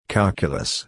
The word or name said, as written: calculus